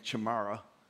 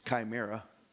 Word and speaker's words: chimera: My son had to correct my pronunciation of this word. I was saying chimera, it's chimera.